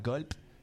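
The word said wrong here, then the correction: Golpe: Golpé, c'est notre première.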